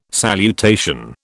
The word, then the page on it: salutation: British English